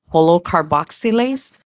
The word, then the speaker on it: holocarboxylase: If you go into Google and you type in two siblings surviving holocarboxylase, it sounds just the way it's spelt the same way. So holo, it's H-O-L-O, car, C-A-R, boxy, B-O-X-Y-L-A-S-E.